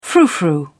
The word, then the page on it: frou frou: British English